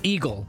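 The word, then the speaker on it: Igel: And it's pronounced eagle. So I thought that's confusing. – Eagle? – Yeah, it's I-G-L-E, but you say it eagle.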